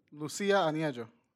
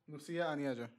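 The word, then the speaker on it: Lucia Aniello: Can you pronounce the director's name for me, please? – Lucia Aniejo. – Lucia Aniejo. – Thank you.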